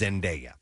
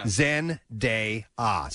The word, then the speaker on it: Zendaya: It's Zendaya. – Zendaya. Oh! – It's actually phonetic if you look at the name. – Oh, yeah. – Zendaya. So that is how she says it.